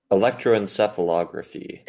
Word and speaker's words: Electroencephalography: Electroencephalography.